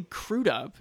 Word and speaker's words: Crudup: Edgar Hoover, played by Billy Crudup.